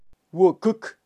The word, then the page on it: wukuk: Kaqchikel